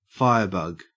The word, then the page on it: firebug: Australian English